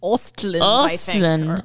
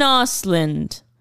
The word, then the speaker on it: Östlund: Östlund. – Ruben Östlund.